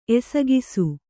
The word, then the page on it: ಎಸಗಿಸು: Kannada